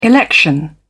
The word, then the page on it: election: British English